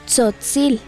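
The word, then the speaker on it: Tzotzil: ¿O cómo se dice? – Se dice Sotzil. A ver, repite. – Sotzil.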